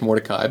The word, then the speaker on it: Mordecai: And how do you say the word Mordecai? Is it Mordecai, Mordecai, which I believe it's Mordecai.